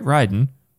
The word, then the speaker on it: Raiden: Raiden.